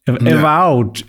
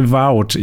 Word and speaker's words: Avowed: Äh, das nächste, was gezeigt wurde, Evolved. Avowed, ja. avowed, ich kann es nicht aussprechen. – Wie, avowed. Wie wow, avowed.